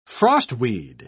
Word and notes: frostweed: British English

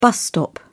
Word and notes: bus stop: British English

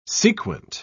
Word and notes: sequent: British English

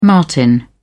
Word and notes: martin: British English

